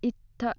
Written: itta

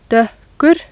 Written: doekkur

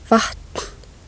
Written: vatn

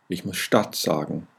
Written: stadt

